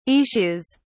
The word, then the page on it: issues: British English